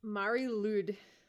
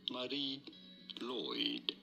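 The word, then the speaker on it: Mari Lwyd: In Welsh, this is said as Marie Lloyd.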